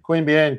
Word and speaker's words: Queanbeyan: Queanbeyan, because I said it incorrectly in the introduction.